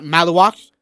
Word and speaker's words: Maluak: Uh, I always have a hard time pronouncing his name. Makuak. Maluak? – Uh, yeah, Maluak.